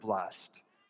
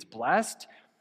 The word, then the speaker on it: blessed: I say it the right way. It's blessed. So at the end, there's like a T for the ED. It's blessed. He says it's blessed. So he adds the extra syllable to the end. It's blessed. And then there's still other people that just say blessed.